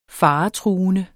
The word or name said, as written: faretruende